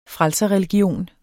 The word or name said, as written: frelserreligion